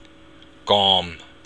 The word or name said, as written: gom